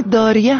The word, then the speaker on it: دایره: دال مثل دایره، یکی از سازهای کوبه‌ای اصیل و قدیمی دایره است که در گویش عوامانه داریه هم تلفظ میشه.